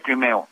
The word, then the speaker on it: Crimo: Dices que es eh, que, se, que se pronuncia crimeo. – Es correcto, sí, es, es uno de los apellidos raros, es como crimeo, pero se escribe crimo. Es Robert E. Crimo III, pero se pronuncia crimeo.